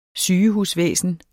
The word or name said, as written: sygehusvæsen